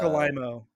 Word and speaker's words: Alaimo: Marc Alaimo. Marc Alaimo.